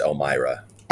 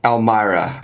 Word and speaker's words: Elmira: Yeah, it's about a 4.5 hour drive northwest, pronounced Elmira. – Elmira.